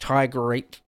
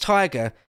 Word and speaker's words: TYGR8: Okay, and the last one from that same person, TYGR8, don't know how you would say that, Tigerate, says, oh, they just say, it's pronounced Tiger, just stylized for YouTube, haha. Okay, it's just Tiger.